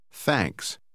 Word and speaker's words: Thanks: Achten Sie darauf, dass die Buchstabenfolge TH manchmal hauchiger ausgesprochen wird. Lesen Sie Nummer 13 noch einmal. – Thanks.